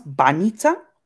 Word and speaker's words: banitsa: It's pronounced banitsa. – Banita. Okay. – Banitsa. Yes.